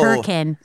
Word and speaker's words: Hurricane: Well, it's spelled hurricane, but apparently everyone says hurricane.